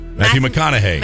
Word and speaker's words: McConaughey: It's Matthew McConaughey.